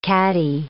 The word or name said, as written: caddie